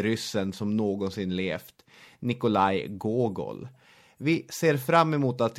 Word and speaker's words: Gogol: Jonas Nordin har förklarat för mig att man uttalar det Gogol.